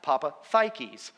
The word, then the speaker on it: Papadakis: The Greek pronunciation of the last name is, in fact, Papadakis. I was like, huh, okay. I'm 51 years old, and I now know how to pronounce my last name.